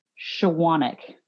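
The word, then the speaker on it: Chowaniec: It's actually Polish. It's pronounced Shawanic.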